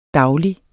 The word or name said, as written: daglig